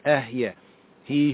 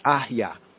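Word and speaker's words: ehyeh (אהיה): Now, some latter-day Hebrews and Israelites, they mispronounce it. They call it correct, but it's really incorrect if we study Hebrew more comprehensively, right? But it's baby Hebrew, all the same. They will say it's ahyeh, ahyeh.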